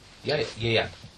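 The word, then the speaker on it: Ieuan: Yeyan. – Yeah, like the rugby player, yeah, it's spelled Yeyan Evans. – Yeah. – Not like yeah. Him. – Yeyan.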